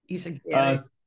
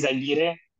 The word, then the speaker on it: Izaguirre: Izaguirre. Tomato, tomato. The way I pronounce it is Izaguirre, but...